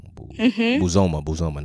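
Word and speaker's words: Bozoma: Is the name Buzama St. John? – Yes, right. Thank you for looking it up. – Buzoma. I think I pronounced that wrong. Buzoma, not Buzama.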